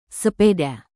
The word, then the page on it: sepeda: Indonesian